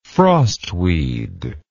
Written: frostweed

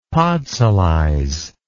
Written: podsolize

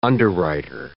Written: underwriter

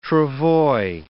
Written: travois

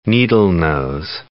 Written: needle-nose pliers

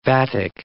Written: vatic

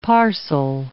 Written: parcel

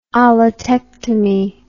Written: allatectomy

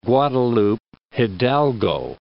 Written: Guadalupe Hidalgo

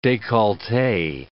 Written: decollete

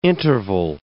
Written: interval

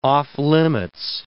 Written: off-limits

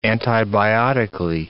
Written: antibiotically

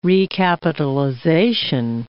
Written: recapitalization

